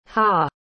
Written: haugh

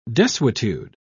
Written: desuetude

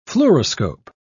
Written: fluoroscope

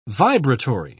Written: vibratory

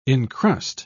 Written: encrust

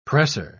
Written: pressor

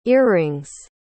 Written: earring's

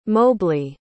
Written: mobley

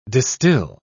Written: distil

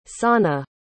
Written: sana

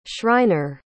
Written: schreiner

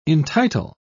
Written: entitle

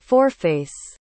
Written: foreface

